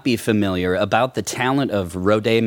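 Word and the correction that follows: Rodaidh: McDonald? – Oh, Roddy. – Roddy. – Yeah, he's from Scotland, and so his name is spelled very intensely.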